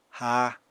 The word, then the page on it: ha: apatani